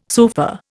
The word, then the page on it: sofa: Indian